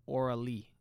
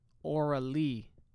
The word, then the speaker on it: Auralee: That's a brand called Aura Lee. – Excuse me? – Aura Lee.